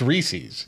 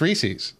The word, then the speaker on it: Reese's: No, it's Reese's. You were like, it's pronounced Reese's. Like Reese's Pieces.